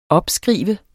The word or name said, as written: opskrive